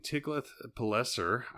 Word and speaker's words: Tiglath-Pileser: But there was a man named Tiglath-Pileser, I think is how you say that.